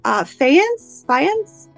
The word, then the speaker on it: faience: The material is Egyptian, correct me if I'm pronouncing this wrong, faience? – Yeah, that sounds right.